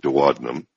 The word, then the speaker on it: duodenum: And this is below your stomach, and it's called the duodenum, or in some countries they pronounce it duodenum. Tomato, tomato, however you like.